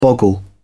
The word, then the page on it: boggle: British English